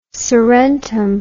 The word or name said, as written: surrentum